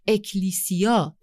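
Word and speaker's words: اکلیسیا (ἐκκλησία): معنای واژه کلیسا در زبان یونانی که اکلیسیا تلفظ میشه، یعنی گروهی از افراد دعوت شده برای بیرون رفتن.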